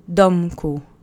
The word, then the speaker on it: domku: Accent is on the first syllable. Domku.